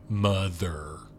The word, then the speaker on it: MUTHUR: They call the computer Mother because it's the actual name of the computer is MU-TH-UR. – Mm-hmm. – Mother. Try to pronounce it. MUTHUR. Mother. Mother.